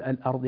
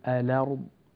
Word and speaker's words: الأرض: كلمة الأرض ورش ينقل فيها حركة الهمزة إلى الحرف بعدها، ينقل فيها حركة الهمزة إلى الحرف الذي بعدها، لا يقول الأرض، يقول الأرض الأرض الأرض.